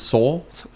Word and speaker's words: Seoul: It's actually pronounced Seoul. Two syllables. Seoul. – Seoul. – Seoul. But it's written Seoul. S-E-O-U-L. So Seoul. So it's pronounced Seoul in English, like your soul, spirit. Soul. – Yep. – But it's supposed to have two syllables. Seoul.